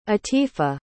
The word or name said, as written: atifa